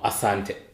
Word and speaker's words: asante: Asante.